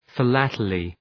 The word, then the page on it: philately: fı’lætəlı